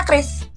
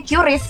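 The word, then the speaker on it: QRIS: kenapa dibacanya Chris kan itu depannya hurufnya Q. Kenapa nggak dibacanya Curious gitu.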